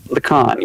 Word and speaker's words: Lacan: Lacan.